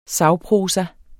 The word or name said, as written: sagprosa